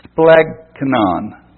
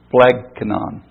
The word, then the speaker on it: splagchnon: The word for mercy in the Greek is splegkanon, and that is spelled S-P-L-A-G-C-H-N-O-N, splegkanon.